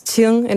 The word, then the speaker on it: Qing: Dat is Qing in het Mandarijn. En er stond dan, door heel veel leraren wordt het uitgesproken als King, want die weten niet wat ze ermee moeten.